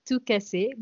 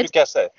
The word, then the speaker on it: tout casser: So it's you should pronounce it TOUT CASSER, but it's quite good. – TOUT CASSER, my apologies.